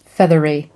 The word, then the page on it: feathery: American English